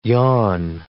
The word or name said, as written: yawn